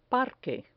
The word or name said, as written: parque